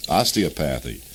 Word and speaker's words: osteopathy: Osteopathy.